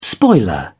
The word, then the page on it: spoiler: British English